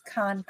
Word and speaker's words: Concord: I was corrected when I called it Concord. It's Concord. – Oh, yeah, I was too. – I think I hear it pronounced Concord.